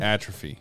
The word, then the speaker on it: atrophy: Detrophy, tell. – Like, is that how you say it? Detrophy? – Atrophy. – Atrophy.